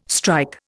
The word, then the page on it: strike: Indian